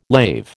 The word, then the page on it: lave: American English